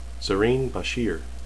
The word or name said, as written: zarine